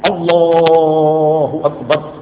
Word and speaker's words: اللہ اکبر: سیدھا تلفظ کیا ہے؟ اللہ اکبر، یہ صحیح تلفظ یہ ہے. ایک تلفظ کیا؟ اللہ، یہ معنی فاسد ہو جائیں گے، نماز بھی فاسد ہو جائے گا، اللہ کو آ اللہ کر، اور ایک اللہ اکبر، یہ اس کو آگ کر دیں